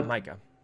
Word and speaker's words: Micah: But I don't know how you say it correctly, Mika or Micah. – Micah.